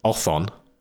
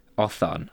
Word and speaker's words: otthon: So here it's spelled O-T-T-H-O-N. – Othon. I like that a lot. – Because it sounds a bit like a thorn.